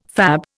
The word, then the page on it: fab: Indian